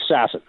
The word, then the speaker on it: Sasson: Is it Sasson or Sasson? – It's Sasson. – All right. That's Rick Dhaliwal has led me astray, predictably. He was saying Sasson, so I said it.